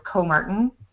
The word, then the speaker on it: Comartin: So it's, my last name is Comartin at oakland.edu.